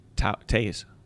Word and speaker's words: Toews: How do you say Toews? – Taze.